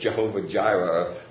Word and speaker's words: Jehovah Jireh: He's more than just Jehovah Jireh or Jehovah Yireh, I guess is the way they pronounce it in Hebrew.